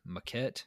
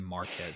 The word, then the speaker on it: Maquette: Simulation puzzle game Maquette. I want to say Marquette so bad. – It's like Baguette. – It's like Baguette with an M.